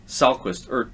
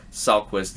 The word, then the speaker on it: Salquist: Chicago Fire today announced that the club has acquired defender Tobias Salquist, as the pronunciation guide here lists it, Tobias Salquist, via transfer from Danish club Silkeborg IF.